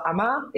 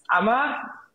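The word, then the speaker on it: Amager: you probably will have passed by the stop Amma if you're coming from the airport, which is Amma. Any guesses on how that's spelled? It's A-M-A-G-E-R. So the G-E-R is silent.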